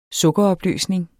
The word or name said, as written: sukkeropløsning